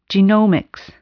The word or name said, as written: genomics